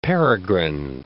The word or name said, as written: peregrine